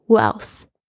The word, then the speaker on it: wealth: 呃，满的这个形容词加上 n e s s 变成喜变成名词，你会感觉到喜悦还有满足，but no wealth，但是没有财富 wealth w e a l t h wealth，注意一下这个 e a 是发 a 的声音，然后加上 l 加上 t h 的发音 wealth 不是很好念，但是一定要记住它的念法 wealth。